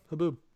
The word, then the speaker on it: haboob: Haboob. – That is wild. – It's spelled like you think, too.